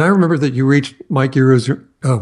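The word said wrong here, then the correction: Eruzione: Mike Ruzioni, yeah.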